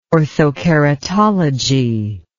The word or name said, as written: orthokeratology